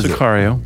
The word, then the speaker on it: Sicario: He was in, I saw recently in Sicario, am I saying it right? What is Sicario. – It? Sicario. Sicario? Okay, mm-hmm.